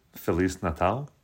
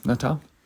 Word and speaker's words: Feliz Natal: How do you say it in Portuguese? Feliz Natal? – Yeah, good job. Okay. Natal.